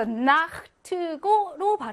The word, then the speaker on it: Nacht: 요 Nacht의 체하를 어떻게 발음하냐인데요. 자, 아나, 오나, 우나, 아우 다음에 체하가 오면 이 아, 오, 우, 아우라는 모음들은 얘네 모음은 이혀 중에서도 혀 뿌리 쪽에서 뒤쪽에서 후설로 나기 때문에 아, 오, 우, 이게 아, 오, 우 뒤쪽에서 나기 때문에 목구멍 마찰음인 체하, 흐, 아, 오, 우, 이렇게 가까운 쪽으로 나는 게 좋다는 거지. 그래서 나흐트고로 발음하는 게 좋다는 거예요.